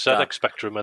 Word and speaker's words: ZX Spectrum: Am I saying it right? – Yeah, it is. ZX Spectrum, and then there was a Spectrum Plus, which was like had an actual keyboard instead of rubber keys.